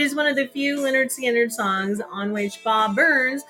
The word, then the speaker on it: Lynyrd Skynyrd: Tuesday Gone, Lynyrd Skynyrd, pronounced Lynyrd Skynyrd, 1973.